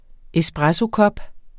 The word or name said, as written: espressokop